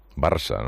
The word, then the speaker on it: Barça: El único que sería de España era primero Spain en inglés y segundo Barca. – Barça, ¿no? Barça, claro, Barca. – Claro, Barça, Barca, pero claro, como que en inglés la C catalana no existe, y si pronunciamos Barca, ¿no?